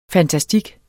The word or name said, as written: FANTASTIKKEN